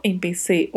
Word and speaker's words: empecé: Eu empecé ou eu comecei Atenção para a pronúncia. Letra E sempre fechada e a única sílaba aqui que tem uma particularidade de pronúncia mais específica é a última, por conta da letra C. Este som nesta sílaba pode ter som de S. Em vários países hispanohablantes se fala em PC mesmo, e em outras regiões o som é gerado colocando a língua entre os dentes.